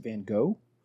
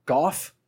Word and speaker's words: Van Gogh: I prepared the boys in advance that here in America, we say Vincent Van Gogh. I don't know why, but in pretty much everywhere else in the world, they either pronounce it Goff or Gogh, because that's really what GH is. Mm-hmm. It's a guttural. That's a glottal stop.